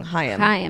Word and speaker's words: Haim: Haim. I know I always say it wrong. Haim. – They say it's like L'Chaim, but Chaim. Lose the L.